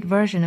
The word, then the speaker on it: version: Always be a first-rate version of yourself, instead of a second-rate version of somebody else. 首先我们来看发音。 Always be a first-rate version of yourself. 版本这个词读成 version 或者 version 都是可以的。后面的 s-i-o-n 读 shin 或 zhin 都 OK。那前面的 v-e-r 呢英音读成 ver, 美音读成 ver。对应下来我们来读 version 或者 version。